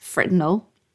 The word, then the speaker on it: Fresnel: The trick, it seems, is to use thin lenses like a Fresnel, pronounced Fresnel, apparently, sheet lenses or a magnifying glass.